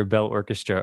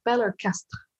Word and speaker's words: Bell Orchestre: because I've been saying either Bell Orchestra or Bell Orchester, but they're both wrong. So it's a French pronunciation, and I'm not even saying it. If I went really French on you, I'd be like Bell Orchestre.